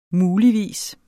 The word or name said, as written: muligvis